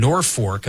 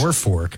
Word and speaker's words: Norfolk: Also, Terry from Norfolk, I'm saying that correctly as well, yes. – Norfolk.